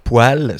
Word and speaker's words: poêle: La vitrine linguistique de l'Office québécois de la langue française nous explique que le mot poil se prononce comme poil de chat.